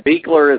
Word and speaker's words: Buechler: Buechler is the correct pronunciation.